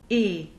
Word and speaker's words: et: The exception to this rule is the conjunction e, which is spelled et and means and. The t of e is never connected with anything that follows it.